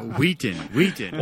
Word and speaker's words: Wheaton: Did you say Wheaton or Wheaton? – Wheaton. – Why are you pronouncing that? – Why are you putting so much emphasis on the H?